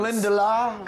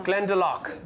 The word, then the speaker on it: Glendalough: How do I say this brand now? – I told you we like to make things hard to pronounce. – Glendalough. – Glendalough. – Glendalough. – So think of your locks here, or think of the Loch Ness Monster.